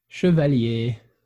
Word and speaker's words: Chevallier: Chevallier.